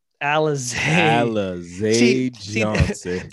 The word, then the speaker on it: Alize Johnson: You gotta say that right, bro. – I'm sorry. – Alize Johnson. – That's one of those things where like he decided that's how it was pronounced.